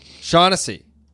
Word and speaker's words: Shaughnessy: Dan Shognosny? Shognosny? – I thought you were just like making up some... Dan Schnagalaga. – How do you say that? – Shaughnessy? – Shaughnessy. Dan Shaughnessy.